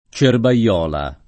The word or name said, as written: Cerbaiola